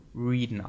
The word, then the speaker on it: Wreden: Äh, ich, also er wird geschrieben W-R-E-D-E-N. Und ich glaube, er spricht sich selbst in dem, in dem Spiel Reeden aus.